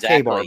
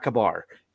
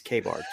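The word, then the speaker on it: KA-BAR: It's pronounced K-bar, by the way. I was screaming, not Kabar. It's K-bar. The knife is a K-bar knife.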